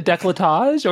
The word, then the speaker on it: décolletage: Right, but I think it's décolletage, isn't it? – No, it is. You are correct.